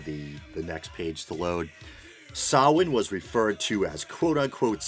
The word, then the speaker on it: Samhain: Its origins can be traced back to the Celtic Irish holiday of Samhain, spelled Samhain, actually pronounced Samhain.